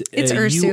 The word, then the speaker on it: Ursu: It's Ursu. – U-R-S-U,